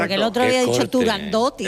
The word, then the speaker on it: Turandot: Turandot no, se dice Turandot, que es... – Como lo pronunciaba, Puccini. Puccini y como lo pronunciaba Toscanini.